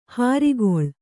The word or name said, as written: ಹಿರಿಕೊಳ್